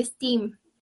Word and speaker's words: STEAM: También se le agrega a veces la A, que pronuncia STEAM, porque la A es de artes y a veces hay tecnología involucrada, pues como la animación, que em, visión...